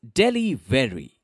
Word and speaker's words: Delhivery: Together, they came up with the name Delhivery, which is pronounced as Delhivery.